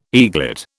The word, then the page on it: eaglet: British English